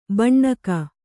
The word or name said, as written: ಭೂನಾಗ